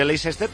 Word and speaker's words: Leicester: Pronunciamos Leicester, se escribe Leicester, pero es que allí en Inglaterra le pronuncian Leicester.